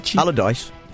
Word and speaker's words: Allardyce: The Telegraph claims it has footage from August of Aladici. That's pronounced right. – Are you calling Aladici? Him Aladici? – Allardyce. – Allardyce. – Yeah. – We're going to go with that.